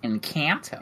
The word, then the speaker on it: Encanto: Oh, Encanto? Whatever, yeah. Encanto, whatever you pronounce it. – Encanto.